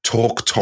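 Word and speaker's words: Twrch Trwyth: He was hunting Torctowith, and I have looked up how to pronounce that. Torctowith.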